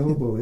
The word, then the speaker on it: Hoboken: I'm gonna tell you that Hoboken. shit right now. Hoboken is fun. That's what they, they oh, said, you said it right the first time. Yeah, they said Hoboken. they said yeah Hoboken.